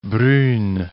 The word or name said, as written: Brunn